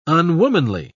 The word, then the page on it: unwomanly: British English